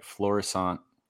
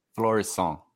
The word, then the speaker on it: Florissant: Today's guest, Loden Sports Outlier Sessions, hailing from, and let me know if I say this right, Florissant. – Yeah. – Florissant. It's kind of French.